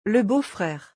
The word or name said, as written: Le beau-frere